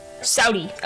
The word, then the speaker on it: Saudi: Ah, wait, how do you say it? Saudi. Meaning. – Saudi.